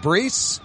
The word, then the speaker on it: Brees: I think it's pronounced Brees. Yeah, you right.